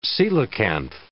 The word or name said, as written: coelacanth